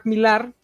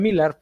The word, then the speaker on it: Millar: Eh, pero pues el niño mame de estas dos semanitas ha sido que Mark Millar, de hecho se pronuncia Millar, pero me gusta pronunciarlo Millar porque sé que Millar lo odia,